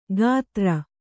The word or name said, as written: ಗಾತ್ರ